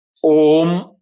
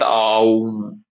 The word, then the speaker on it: om: So om, when it is said that, it is om only. And I've heard people talking aum. That is wrong way of pronouncing it. It is not aum.